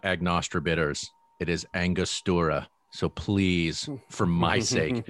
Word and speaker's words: Angostura: Label and I was like, oh my God, it is Angostura. Because I did is. The same you're right. – thing. – You're right. It is Angostura.